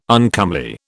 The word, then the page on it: uncomely: British English